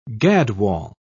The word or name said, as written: gadwall